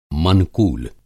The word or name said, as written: मनकूल